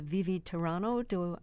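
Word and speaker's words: Vivi tiranno: This is from Handel's opera Rodelinda, and this is the Vivi Tirano. Am I pronouncing that correctly? – Perfect.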